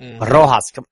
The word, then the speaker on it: Rojas: You gotta roll your R's. You know how to roll your R's. You know how to do it. So do it. Rojas, come on.